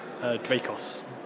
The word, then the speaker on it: Dracos: Dracos. – Spell that? – D-R-A-C-O-S. – D-R-A-C-O-S.